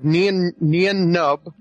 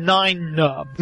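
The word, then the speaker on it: Nien Nunb: I think that Adam Driver will actually play the character of the love child of Nian Nub. And, I always say his name Nine-Nub.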